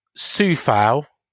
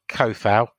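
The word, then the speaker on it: Souček: Uh, he took the gamble of putting, um, here we go, Sufal directly in, not Kofal, Sufal uh, directly in, because you don't pronounce C in, in Czech Republic, by the way.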